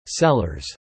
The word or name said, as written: sellers